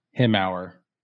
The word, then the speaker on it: Hemauer: So it's pronounced Hemauer. – Hemauer. – Hemauer. It looks like Hemauer, but it's pronounced Hemauer. That's the head brewer's last name.